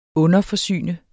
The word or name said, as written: underforsyne